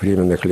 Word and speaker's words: Временных: Ударение именно так нужно делать, «Временных лет».